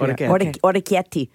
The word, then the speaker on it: orecchiette: And then also a new favorite on my little Mount Rushmore pasta is a little orecchiette. – Those are great. – Mm-hmm. So good. – Yeah. – Mm. Bosman? – Are we saying that right? – Yes. – Orecchiette.